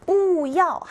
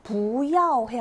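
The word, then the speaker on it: 부야오 (不要): 뿌야오 하지 않고 부야오 해야 맞는 거예요. 뿌야오가 아니라 뿌가 뒤에 사성과 함께 올 때는 부야오 이렇게 읽힌다.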